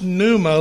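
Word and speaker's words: pneuma (πνεῦμα): The P is silent, and so it's pronounced pneuma, like pneumonia.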